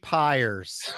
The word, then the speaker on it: Pires: Augie Pyers. – It's all right. There's like 15. I don't know why P-I-R-E-S is the hardest word to pronounce, but there's about 77 different pronunciations of that last name.